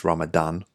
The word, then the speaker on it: Ramadan: They were done before Ramadan, um, they were done in the beginning of Ramadan, and uh, apologies, I definitely mispronounced Ramadan. It's Ramadan, um, at least if it's spelled correctly in this study.